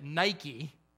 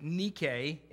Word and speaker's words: Nike: We pronounce it Nike. They probably pronounced it Nike in the Greek.